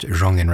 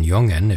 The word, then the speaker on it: Jongen: And now we hear from an organist from Wallonia, the French-speaking region of Belgium, which is why Joseph Jongen is pronounced Jongen rather than Jongen if it were more in the Dutch part of Belgium.